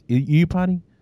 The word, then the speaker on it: Iupati: Speaking of the offensive line, you added Mike Iupati. You stole him from the that's how you pronounce it, right? Is it Iupati? – Iupati, yeah. – Iupati, there you go.